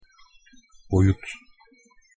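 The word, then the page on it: boyut: Turkish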